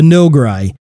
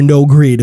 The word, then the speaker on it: Noghri: way back have been brought in as part of canon, like Grand Admiral Thrawn, uh, the Nograi, uh, or Noghri, depends on how you want to pronounce it, which is a race of people, have, have, been brought in.